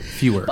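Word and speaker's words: Feuer: I think it's Fuhrer, like in the Fuhrer. – Fuhrer. – But Fuhrer. Okay, thank you. Fuhrer, like Fuhrer.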